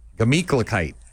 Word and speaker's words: Gemütlichkeit: No, hold on. I'm going to get you to pronounce it properly. Here we go. Guh. – Guh. – Meat. – Meat. – Cla. – Cla. – Kite. – Kite. Gla meat. – Not a gla. Guh. Just G-A. Guh meat. Gamikla kite.